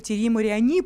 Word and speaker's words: Тьерри Мариани: И как и в прошлом году, его возглавлял депутат Нижней палаты французского парламента, законодательного собрания от партии «Республиканцы», Тьерри Мариани. Но в этот раз... – Простите, вас поправлю немножечко. Тьерри Мариани, потому что это французы. У французов идет ударение на последний слог.